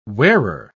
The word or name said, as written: wearer